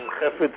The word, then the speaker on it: Chafets: And Hafetz is the way it's pronounced in Hebrew, but in English it's spelled C-H-A-F-E-T-S, Chafetz.